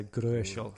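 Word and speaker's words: Gröschel: O umlaut, so it's you can pronounce it a bit like an O E, so Gershel.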